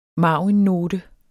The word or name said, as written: marginnote